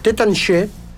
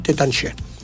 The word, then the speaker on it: Taittinger: Se lee Taittinger. – Se escribe. – En castellano, pero en francés la pronunciación es Tétanché. – Lo tengo yo. – Sí. – Sí, porque la E y la R no se pronuncian en francés.